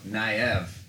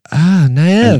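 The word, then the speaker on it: Niamh: So, Naive, two syllables, Irish language feminine given name, meaning bright or radiant. So it's Gaelic. – It's gay. Okay, it's Irish. – Okay. – Naive.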